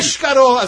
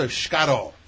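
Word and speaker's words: escarole: And again, Escarol as opposed to Scarol.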